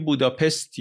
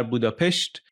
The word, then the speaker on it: بوداپست: الان هم بهش میگیم بوداپست یا حالا به تلفظ درست‌تر بوداپشت، ولی در واقع اینا سه تا شهر بودن.